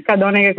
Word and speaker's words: Cadoneghe: Allora, il Centro Spinelli appunto è a Cadoneghe, Cadoneghe con l'accento sulla O,